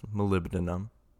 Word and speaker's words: molybdenum: It's kind of spelled molybendum, but I believe it's pronounced molybdenum. Molybdenum.